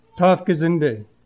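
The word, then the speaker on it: Taufgesinnte: Okay, I'm not saying it right. Huh? Taufgesind is baptism-minded. That's what I was trying to... How did you say it? Taufgesinde.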